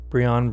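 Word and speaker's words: Brion: John Bryan, I believe that's how you pronounce his last name. It's B-R-I-O-N, Brian, Bryan.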